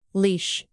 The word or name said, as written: leash